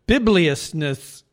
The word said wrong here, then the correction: bibulousness: Is how it's pronounced. – Bibulous. – That bibulous. – Bibulousness. Bibulousness.